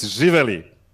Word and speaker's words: Živeli: Okay, first of all, it's Živeli. – Živeli. – Okay, Živeli. – Nobody here should be able to pronounce that. – Say it again. – Don't make it long. It's Živeli. – Živeli. So there's like a different emphasis. Živeli. I think that's what we're missing. Živeli.